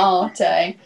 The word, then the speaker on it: dell'arte: I can't say it right because I say latte at the end instead of commedia dell'arte. That's what I thought. My brain went. – Arte.